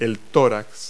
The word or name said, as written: thorax